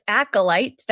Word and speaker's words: Acolyte: Uh, the newest series, Star Wars Acolyte. Did I say that right? – Yes. Mm-hmm. – Okay, perfect.